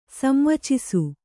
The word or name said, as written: ಸಂವಚಿಸು